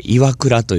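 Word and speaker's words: 岩座: これを岩に、まあ、座ると書いて、岩倉と読むわけですね。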